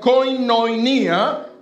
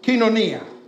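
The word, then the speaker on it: kinonia (κοινωνία): It sounds, phonetically, it sounds like koinonia, right? Koinonia, I'm sorry, but it's not koinonia. It's pronounced kinonia.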